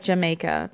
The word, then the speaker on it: Jamaica: They often make a beverage known as Jamaica, it's spelled like Jamaica, but it's pronounced Jamaica.